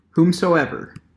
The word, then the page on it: whomsoever: American English